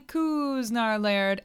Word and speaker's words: Kuznar-Laird: I am your host, Emily Kuznar-Laird, emphasis on the ooh.